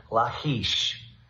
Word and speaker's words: Lachish: And not only that, the city of Lachish, or our Jewish friends would correctly pronounce it, Lachish.